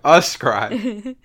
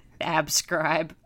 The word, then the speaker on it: ascribe: You're not even pronouncing the word right. – Ascribe. – Abscribe.